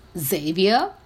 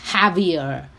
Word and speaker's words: Javier: And the way I pronounced it was Xavier, and I actually didn't know that it is a Spanish word, and you're supposed to replace the J with an H and say it as Javier.